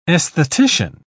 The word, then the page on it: aesthetician: British English